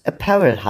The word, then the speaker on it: apparel: Wir wollten nochmal hinzufügen, dass es Apparel heißt, nicht Apparel, lieber Hauke. Die Betonung liegt auf dem A.